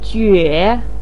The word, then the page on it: jue: 3